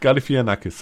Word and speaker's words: Galifianakis: Zach Galifianakis, so wird es ausgesprochen.